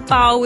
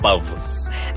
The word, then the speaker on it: pao: Why is it with an O and not with a V when most people spell Pow with a V? – Because it's Pow, not Pav.